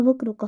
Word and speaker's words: 暴露: 一つ、暴露って読むのこれ。ああ、暴露か。